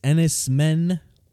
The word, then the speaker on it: Enys Men: Let's move on to our next featured review, which is Enys Men. I think that's how it's pronounced. – Yep.